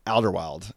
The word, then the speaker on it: Alderweireld: How do you say that name? – Toby Alderweireld. Yeah, 29 years old from Spurs.